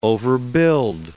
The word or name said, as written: overbuild